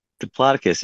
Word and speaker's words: Diplodocus: Diplodocus, yeah, thank you. If you say it correctly, that's how you say it.